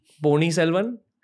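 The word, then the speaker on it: Ponniyin Selvan: Secondly, Pony Selvan.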